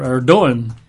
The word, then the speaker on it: Erdogan: Mr. Erdogan, you write that E-R-D-O-G-A-N, but pronounced Erdogan.